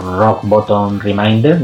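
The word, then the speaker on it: Rock Bottom Remainders: y sobre lo demás, bueno, tenemos a King, que en lo más personal, con su grupo, los Rock Bottom Reminder, no sé si lo habré pronunciado bien. – Sí, no está mal.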